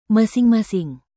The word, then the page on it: masing-masing: Indonesian